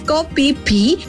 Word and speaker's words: Koh Phi Phi: Bueno, en el puesto número 5 vamos a encontrar a las impresionantes islas Koh Phi Phi en Tailandia.